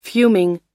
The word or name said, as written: fuming